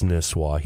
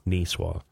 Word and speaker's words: Nisswa: John knows it's Nisswa. He just mispronounced it by accident. So don't send angry letters to John. Nisswa.